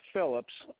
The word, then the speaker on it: Phillips: Jan, J-A-N, and the last name's Phillips, P-H-I-L-L-I-P-S.